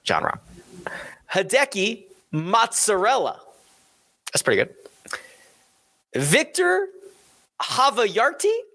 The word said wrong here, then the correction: Havarti: Hovayarty. Did I say that right? Does that sound right to you? – I think it's a Havarti. – Havarti. – Yeah. – Yeah, that would make more sense.